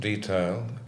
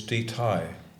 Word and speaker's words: Deception: Petro, last night, made the point, she said, it isn't Detail, it is Detail. – Yes. – And that is the correct pronunciation, but I'm afraid I will still call it Detail.